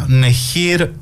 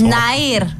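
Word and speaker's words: Nehir: Και 1980, Νεχίρ Ερντογάν, Τουρκάλα ηθοποιός. Δεν ξέρω αν έχει καμιά σχέση και με το... – Ναΐρ. – Τι, Νεχίρ. – Ναΐρ. – Ναΐρ. – Έτσι προφέρεται.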